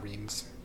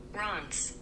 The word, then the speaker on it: Reims: It's not Reims. – No, of course it's not. – Rons. Pronounce or sounds like R-A-A-N-S.